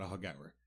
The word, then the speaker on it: Aughagower: Well, before I tell you why, I'd like to tell you some facts about Ahagour. And first, how you spell it. A-U-G-H-A-G-O-W-E-R. Ahagour. Just so you can see why I was in such disbelief. Ahagower is a tiny village located in the west of Ireland outside a bigger town called Westport.